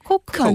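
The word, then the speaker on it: Coke: 네, 콜라 발음 제대로 잘하셨는데 콕, 이게 코크 맞는데.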